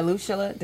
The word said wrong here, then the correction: Alushula: Did I say it wrong? – Alushula. – Alushula and Caesar.